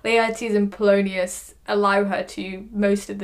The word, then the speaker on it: Polonius: I can't pronounce his name. Is it Polonius? There you go.